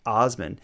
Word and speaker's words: Assman: So Dick Assman is his name, but it's spelled Assman, pronounced Osman.